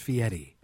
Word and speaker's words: Fieri: It's spelled Fieri, but it's pronounced Fieri.